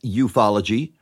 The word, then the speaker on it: ufology: It is pronounced ufology,